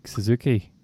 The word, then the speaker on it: Suzuki: Because the guy that won it, I'm going to try and pronounce his name here, Yusuke Suzuki. – Yeah, think of the motorbike, yeah.